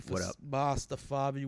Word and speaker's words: Mostafavi: My main man, Ramin Mostafavi, was good.